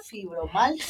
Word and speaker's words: fibromialgia: ¿Fibromialgia? – Fibromialgia. – Fibromialgia. – Mialgia. Esa es la palabra. Ajá, correcta. Es la palabra, okay, fibromialgia.